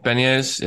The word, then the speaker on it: Beniers: How do you say his last name? – No idea. – Beniers? – Beniers, yeah.